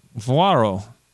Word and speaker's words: vaquero: Vaquaro. – Vuaro? – I don't know how to pronounce it. – Vaquero.